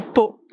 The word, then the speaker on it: Pau: The name of the city is Pau. – Pau? – Pau. How do you spell that? – P-A-U. – Like Pau. – Exactly. – Like, that's peace in Mallorquin. – Yeah, but you say Pau.